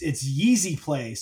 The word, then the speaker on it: YZYSPLY: It's Yeezy Play, so it's YZYSPLY retail. – It's like shorthand.